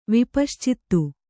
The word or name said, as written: ವಿಪಶ್ಚಿತ್ತು